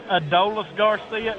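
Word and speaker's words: Adolis García: Going back to what y'all was fussing at Buzz about, and Jack, I think it is Adolis Garcia is how I've heard it pronounced, but you can call him whatever you want to.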